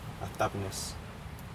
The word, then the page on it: atapnes: Aneityum